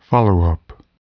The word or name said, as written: followup